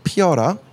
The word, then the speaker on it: Peora: It's actually a Korean word. A lot of people think it's Italian because Peora was Italian-Korean. But pronounced Peora in Korean meaning, Korean definition of Peora is to blossom.